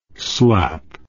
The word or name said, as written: slapp